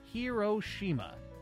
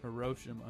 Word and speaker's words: Hiroshima: He pronounced it Hiroshima. Now most say it's Hiroshima.